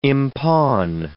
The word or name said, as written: impawn